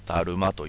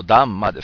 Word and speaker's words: ダンマ: それでですね、まあこのですね、真理ですね、ダルマというふうに言ってますけども、法律の法と書いてダルマと、ダルマというふうにね、正確に言うとダンマですか。まあサンスクリットかなんかでね、まあ元々の発音だとダンマという言い方をしますね。